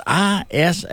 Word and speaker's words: Isaias: As we getting ready for a what could be a chaotic weekend, because everything's on is scheduled right now, but Hurricane Iglesias, is that how you pronounce that? – Close enough, I think. – Yeah, it's Isaias.